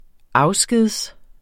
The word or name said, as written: afsked